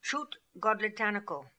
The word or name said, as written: Choot God litanical